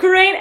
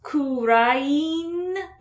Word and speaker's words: Kurain: It's pronounced Kurain, but like, wasn't sure. – Kurain. That's just Kurain. One extra syllable.